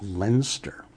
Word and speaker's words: Leinster: He was at the last New York Review of Science Fiction reading event, feating the work of Murray Leinster, along with, which is actually pronounced Leinster. Real name was Will Jenkins, but he named his character, his pseudonym, after the county in England. So it's Leinster, apparently.